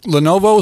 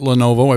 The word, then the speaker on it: Lenovo: Lenovo is fine. Lenovo. I pronounce it Lenovo. I believe that's correct.